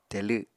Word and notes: telw: apatani

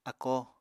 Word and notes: akó: apatani

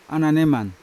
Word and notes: ananeman: Yawa; Unat

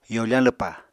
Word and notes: yofañ lwpa: apatani

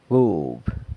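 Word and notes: gub: San Lucas Quiaviní Zapotec

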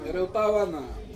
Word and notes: erotawana: Ansus

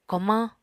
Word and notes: komañ: Apatani

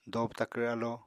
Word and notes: dobw-takvr alo: apatani

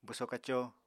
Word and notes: bwso-kaco: apatani

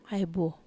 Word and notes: ai buo: Wamesa

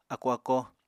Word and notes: ákó-ákó: apatani

